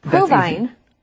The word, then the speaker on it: Provine: Well, it's Robert Provine. – Well, see, that's the thing. I thought it was Provine, So, I'm not sure. – If it's provine or provine.